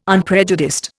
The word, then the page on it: unprejudiced: Indian